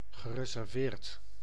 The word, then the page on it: shy: /ʃaɪ/